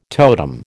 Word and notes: totem: American English

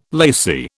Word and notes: lacy: British English

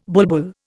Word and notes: bulbul: Indian

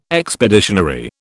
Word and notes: expeditionary: British English

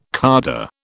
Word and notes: cadre: British English

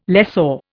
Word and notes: lessor: Indian

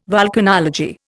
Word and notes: volcanology: Indian

